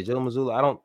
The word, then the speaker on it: Mazzulla: I don't think is it Joe Mazzulla? Is that how you say his name? Yeah, Joe Mazzulla.